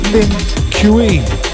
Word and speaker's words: Lin Kuei: It's called Lin Kui. L-I-N-K-U-E-I.